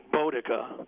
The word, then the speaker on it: Boudica: Actually, we pronounce it Boudica because when I was doing some research, I saw something that said that the OU sound was pronounced like O, long O, like in bow and arrow.